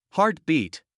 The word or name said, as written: heart beat